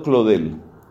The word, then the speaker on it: Claudel: Escriba el nombre de este convertido famoso de principios del siglo XX, Paul, escrito Paul, se pronunciaba Paul, Paul Claudel, pronunciado Claudel, Paul Claudel.